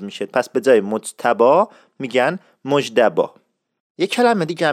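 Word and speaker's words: مجتبی: اسم مجتبا معمولا توسط افراد نزدیک، دوستان، آشنایان و خانواده و اقوام وابسته به صورت مجدبا تلفظ میشد. تکرار میکنم، مجتبا میشد مجدبا، یعنی اون ت به دال تبدیل میشد که این خودش یه مبحثی بسیار پیچیده است و من اینا در جلسات بعدی قشنگ میشکافم.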